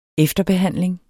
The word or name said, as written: efterbehandling